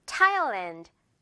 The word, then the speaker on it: Thailand: TH 발음이 아니라 그냥 T 발음만 나면 돼요. Thailand, Thailand 이렇게 발음하잖아요.